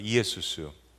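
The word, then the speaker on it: 이에수스 (Ἰησοῦς): 헬라어로는 이에수스입니다. 한번 따라해 보시죠. 이에수스.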